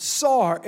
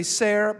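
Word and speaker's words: sar (שַׂר): Sar, it's pronounced sar. Okay? Some would say ser, but sar.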